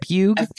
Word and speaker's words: Pugh: How do you say it? Pugh.